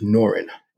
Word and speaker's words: Noryan: So name of the brand, if you first look at it, it's spelled N-O-R-Y-A-N. And a lot of people, some people might call it Norian or Norian. But it is pronounced Norin. I tell people, you know, the Y is silent. It's French, you know.